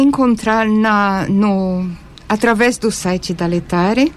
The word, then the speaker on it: Laetare: Letare, que se escreve L-A-E-T-A-R-E.